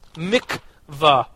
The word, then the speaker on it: mikvah: Um, it's called the the that I want to pronounce it correctly. The the mitz, mikvah. Yes, thank you.